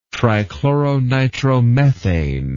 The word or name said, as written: trichloronitromethane